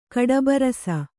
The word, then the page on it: ಕಡಬರಸ: kaḍabarasa